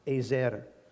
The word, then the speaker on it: Ezer: This is the word Jehovah Ezer, or as it's pronounced, Ezer. E-Z-E-R, Jehovah Ezer.